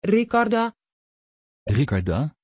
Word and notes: Riccarda: Dutch

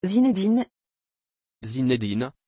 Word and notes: Zinedin: French

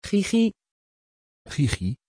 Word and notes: gigi: Dutch

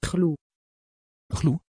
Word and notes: chloë: Dutch

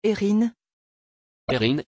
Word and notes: éryne: French

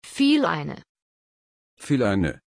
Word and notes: phileine: German

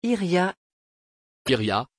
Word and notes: irja: French

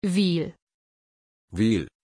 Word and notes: ville: German

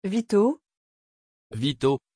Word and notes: vito: French

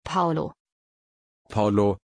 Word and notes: paulo: German

